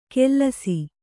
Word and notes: ಕೆಲ್ಲಸಿ: kellasi

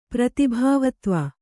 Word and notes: ಪ್ರತಿಭಾವತ್ವ: pratibhāvatva

